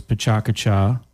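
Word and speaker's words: PechaKucha: You have another full-time job, which is Pachacacha Orlando.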